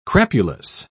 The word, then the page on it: crapulous: British English